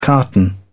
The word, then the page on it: carton: British English